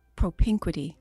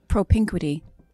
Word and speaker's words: propinquity: So propiniquity, propiniquity. – Propinquity. – Oh, well, that P is way too bougie. How do you say it again? – Propinquity.